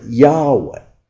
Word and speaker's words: Yahweh: Now, I am is simply four Hebrew letters, YHWH. Nobody even knows what it really means, but they pronounce it Yahweh.